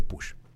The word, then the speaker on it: Puig: Y una de las dos cosas de las que habló el doctor Push es, se escribe Puig, pero se pronuncia Push, por cierto. Para si lo buscan en redes sociales, es Mario Alonso Puig, pero se dice Push.